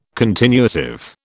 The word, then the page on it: continuative: British English